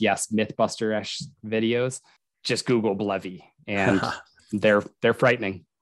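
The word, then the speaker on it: BLEVE: Blevy? – Yeah. – It's B-L-E-V-E. So it's an acronym.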